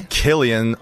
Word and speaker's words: Cillian: It's not pronounced Cillian. – Okay. – It's Cillian, right. Despite the spelling of his name with a C.